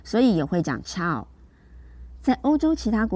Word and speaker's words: Ciao: 它的拼法是 C I A O，Ciao。各位要注意哦，这个 C 的发音很像是注音的七，所以呢，整个呃就是发音应该是 Ciao。 Ciao 就是打招呼，Hello 的意思。